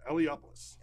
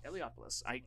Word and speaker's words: Eliopoulos: lettered by Chris Eliopoulos and Rick Parker. Chris, I'm sorry if I pronounced your name wrong. – No, that's right. – Oh, good. – Yeah, Eliopoulos. – Eliopoulos. I sometimes get it right.